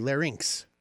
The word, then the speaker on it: larynx: But if you look it up on like Google pronunciation, it's definitely larynx.